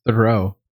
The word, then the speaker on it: Theroux: But essentially, like, the tagline is, like, Justin Theroux and his wife are trying to get pregnant, and they can't. – You're killing me with that pronunciation. – Okay. – It's Thoreau. – Thoreau?